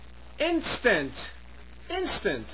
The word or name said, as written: instant